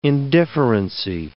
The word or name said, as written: indifferency